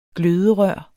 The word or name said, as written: gløderør